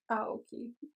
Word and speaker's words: Aoki: Aoki, I believe. Like, Steve Aoki. Like, you know Steve Aoki? – Aoki. Yes, but I don't have to pronounce his name. So, that doesn't help me. That doesn't help me. – I don't know. I mean, okay, I don't want to butcher either. I'm pretty sure it's Aoki. – Aoki? – Aoki. Because, like, I think Aoki. That's how they say it in the show.